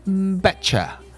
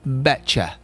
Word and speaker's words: betcha: See ya 比较 natural，比如说我说我给你打赌，Bet you，Bet you，那就会读成。 betcha，这边呢还有一个 t 字母加 e 的一个读法，所以它会读成 betcha。